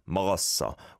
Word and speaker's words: 먹었어: It definitely wasn't 먹었어, but it was maybe just a snitching, a tad, a smidgen away from 먹었어. 먹었어, which would be the fluent Korean way to pronounce this.